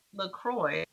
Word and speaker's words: LaCroix: Well, probably LaCroix, like St. Croix.